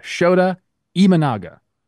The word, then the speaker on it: Shota Imanaga: Right now, we have Shota Imanaga.